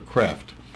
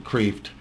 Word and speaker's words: Kreeft: Peter, we're going to look at the ideas of a man by the name of Peter Kreeft, or Kreft. Let's just pronounce it Kreeft.